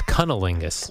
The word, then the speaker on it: cunnilingus: Isn't that how you pronounce it? – He did say that. – And Catherine Zeta-Jones nearly threw him out the house. – I think it's Cunnilingus.